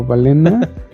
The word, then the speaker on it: Balena: Ah, y este producto que estamos viendo eh, está interesante porque, bueno, no sé cómo, no recuerdo cómo lo pronuncian, es balina o balena. Es Balena Cloud y es para los entusiastas en el IoT.